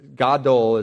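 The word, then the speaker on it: gadol (גדול): Now, the word here for great in Hebrew means, you can go back to the last slide, actually, means either, gadol is what it, is how you pronounce it, can mean either big in size or really important.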